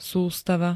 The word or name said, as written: sustava